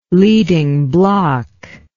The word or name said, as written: leading block